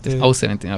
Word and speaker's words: 070 Shake: Zero Seven Zero Shake. 070 Shake, ajá. – Yeah. O-70, ok, es la pronunciación correcta.